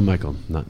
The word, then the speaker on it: Michel: For some reason, it's Virgil Michael. Don't know why, but that's how it's pronounced. – So we're talking about Virgil Michael today.